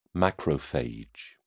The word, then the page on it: macrophage: British English